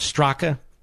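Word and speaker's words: Straka: It's spelled Strocka, but he said it's pronounced Strock.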